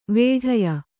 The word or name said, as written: ವಿಡಾಯ